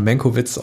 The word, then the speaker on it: Mankiewicz: In der heutigen Folge sprechen wir über Mank, der, wie mein Kollege Arne Wielander schon angemerkt hat, im Deutschen immer Mankowitz ausgesprochen wird, was auch sehr lustig ist.